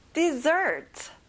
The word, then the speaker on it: dessert: 디저트, 후식, dessert. 마지막 dessert에 힘을 줘보세요.